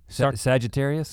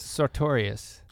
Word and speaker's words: Sartorius: That's where, how do you say his last name? Sagittarius? – Sartorius.